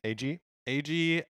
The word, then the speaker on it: Eiji: Eiji